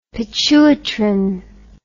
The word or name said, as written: pituitrin